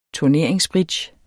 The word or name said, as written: turneringsbridge